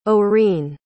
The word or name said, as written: orene